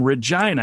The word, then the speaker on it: Regina: This buddy Kelly of mine's from Saskatoon, and he said, Saskatoon, I'm from Regina. Ask him if he knows that. And I was like, he has mentioned that before because I know it rhymes with vagina.